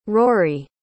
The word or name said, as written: ruairí